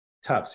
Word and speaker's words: Tufts: And you went to this Tufts. Is that how you pronounce it? – Tufts, yes.